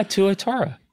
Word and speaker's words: Tuatara: Tuatara.